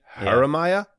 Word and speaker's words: Jeremiah: A soft J. Jeremiah.